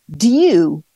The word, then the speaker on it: dew: All right, let's start with do, D-E-W. And I've heard it pronounced, and the dictionary confirmed that some people also say do, like D-Y, do. All right. So D-E-W is water droplets that condense from the air onto cool surfaces.